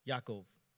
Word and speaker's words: Yaakov: Like Jacob is actually Yaakov in Hebrew, or the way it's pronounced, not Jacob. We pronounce that in English.